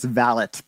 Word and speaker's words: valet: That is, isn't it pronounced valet? – Not if you're British, apparently. There it's valet.